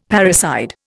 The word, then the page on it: parricide: Indian